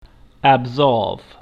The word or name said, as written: absolve